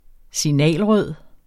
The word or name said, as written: signalrød